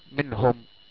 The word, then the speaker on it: منهم: ثانيا، مع الهاء كقول الله عز وجل في كثير من الآيات منهم، منهم النون ساكنة وجاء بعدها حرف من حروف الإظهار الحلقي وهو الهاء، فنطقت منهم.